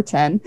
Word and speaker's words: Chen: And it's spelled C-H-E-N, so us Americans and Brits, we all say Chen or Chen. And he's good with that. He said just however it comes out, he answers. But he is in Israel, and it is Chen. So if you are like me and you do try and pronounce people's names correctly, that is how you pronounce it.